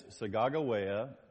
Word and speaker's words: Sacagawea: In the middle, pointing, of course, is not Sacagawea, but join me in the correct pronunciation, Sacagawea That is Sagagawea, most likely a 16-year-old Shoshone Indian girl who was pregnant with the gentleman on the far right.